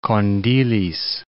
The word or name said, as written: Kondilis